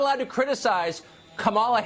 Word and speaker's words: Kamala: Names can be so hard to pronounce sometimes. Even the names of people who have been on the scene for years. Say it with me. Kamala Harris.